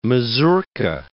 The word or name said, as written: mazourka